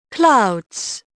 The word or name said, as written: clouds